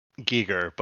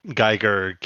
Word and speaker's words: Giger: It's pronounced Giger, but I so many people say Geiger that it's entrenched in my brain.